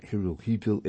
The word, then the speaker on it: Hirohito: That, by the way, that is spelled H-I-R-O-H-I-T-O. Most people pronounce it Hirohito. It is actually pronounced Hirohito.